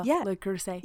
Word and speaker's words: Le Creuset: Le Creuset.